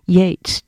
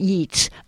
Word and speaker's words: Yeats: And I also had an older sister who loved poetry, and so she would say, it's pronounced Yates, not Yeats.